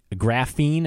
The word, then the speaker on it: graphene: Graphene, is that how it's pronounced, I believe? – Yes.